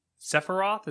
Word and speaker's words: Sephiroth: Ultimate is getting Sephiroth. Is that how you pronounce that? – Yeah, good job.